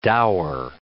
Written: dower

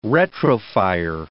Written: retrofire

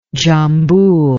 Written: dzhambul

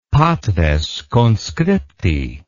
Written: patres conscripti

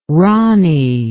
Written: ronnie

